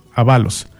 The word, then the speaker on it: Ábalos: Andrés Ábalos o Andrés Ábalos. En la edición que yo tengo es Ábalos, con acento en la segunda.